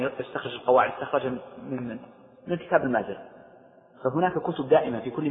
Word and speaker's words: المازري: المالكية عندهم كتاب هو كتاب المذهب لا قبله ولا بعده، ولذلك كل القواعد المستخرجة يرجعون لهذا الكتاب، وهو كتاب شرح التلقين المازري، بعضهم ينطقه المازري وبعضهم ينطقه النازري، والأشهر بالفتح المازري.